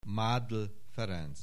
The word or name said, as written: Mádl Ferenc